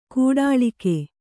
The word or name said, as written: ಕೋಟಲಿಗ